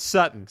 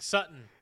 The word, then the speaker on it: Sutton: I said, is it Sutland? – It's Sutton. – Sutton. – It's also not outliner. It's outlier. But hey, we're good. – Cortland Sutton.